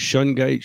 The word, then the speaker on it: shungite: And it's pronounced Shungite, Shungite. There's all kinds of things I've heard people say.